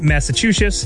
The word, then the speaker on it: Massachusetts: Yeah, and the best states for working moms: Connecticut, Massachusetts.